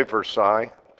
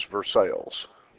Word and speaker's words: Versailles: Did you say Versailles? That would be the correct original pronunciation. – Missouri, right? – As Missourized, it is Versailles. – Versailles.